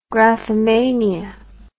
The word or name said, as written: graphomania